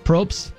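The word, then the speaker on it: Prost: So Jimmy says, Probst, is that how you say it in Germany? Probst?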